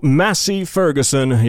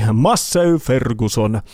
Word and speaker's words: Massey Ferguson: Kyseessä on luonnollisesti Toyotan Takavalot niminen yhtye, jonka uusi sinkku Massey Ferguson, joka käsittääkseni lausutaan oikeasti Massey Ferguson.